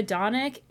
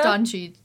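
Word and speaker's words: Dončić: I said Luka Doncic. It's Luka Doncic.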